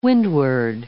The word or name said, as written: windward